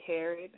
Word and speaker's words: Herod: My Twitter page is I am She Harrod. My last name is spelled H-E-R-O-D.